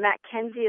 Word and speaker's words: MacKenzie: Okay, Twitter is Leah, L-E-A-H, J as in Jocelyn, just the initial J, and then Mackenzie is my last name, and that's spelled M-A-C, capital K-E-N-Z-I-E.